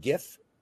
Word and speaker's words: Jif: That's like counting on Jif to make ranch. I mean, like, no, not doing it. – It's pronounced Gif.